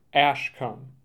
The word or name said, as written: Ashkum